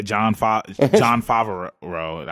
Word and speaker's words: Favreau: John Favreau or Favre? – Yeah, John Favreau, I believe.